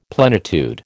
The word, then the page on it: plenitude: American English